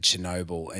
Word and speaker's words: Chernobyl: So I can't Chernobyl, even pronounce guys. It. Well, you got it right.